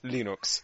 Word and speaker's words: Linux: "My name is Linus Torvalds and I pronounce Linux Linux."